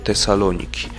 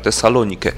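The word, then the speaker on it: Tesalonike (Θεσσαλονίκη): W starożytności wymowa była taka, jak przed chwilą powiedziałem, Tesalonike, natomiast później ta wymowa współczesna też do tego nawiązuje, zaczęto używać nazwy Tesaloniki i stąd potem skrócona jakby nazwa,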